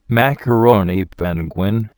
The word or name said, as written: macaroni penguin